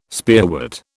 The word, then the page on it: spearwort: British English